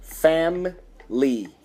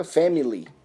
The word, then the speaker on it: family: Uma outra palavrinha também é o family, family, ou seja, o i também não sai, não fica family, My family. Não. Family. Family.